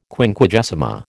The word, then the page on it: Quinquagesima: American English